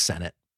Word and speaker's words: Sennett: It's Sennett. Damn it. I knew I said it wrong.